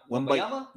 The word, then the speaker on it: Wembanyama: So how do you say his name? Wembanyama. – Wembanyama. Victor Wembanyama.